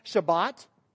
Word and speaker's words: Shabbat: This is the Hebrew word for it. Shabbat is the Hebrew pronunciation on it.